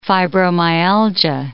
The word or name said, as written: fibromyalgia